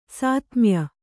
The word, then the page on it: ಸಾತ್ಮ್ಯ: sātmya